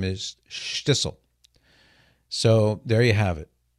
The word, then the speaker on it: Shtisel: How do you spell Shtissel? It's easy. It's spelled SHT. Careful now, SHTISEL. Got that down, Cyrus? SHTISEL, Shtissel.